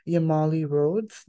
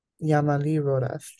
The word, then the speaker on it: Yamali Rodas: Today, we are joined by the incredible Yamali Rodas. Did I pronounce that correctly? – Yamali Rodas. – Yamali Rodas, thank you very much.